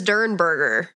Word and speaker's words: Derenberger: His last name is Derenberger. – Word Derenberger. – Yeah. – Yeah. – Not Derenberger. Derenberger.